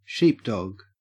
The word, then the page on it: sheepdog: Australian English